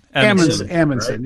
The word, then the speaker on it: Amundsen: Is it? Do you say Amundsen? – Amundsen. Right? Yeah.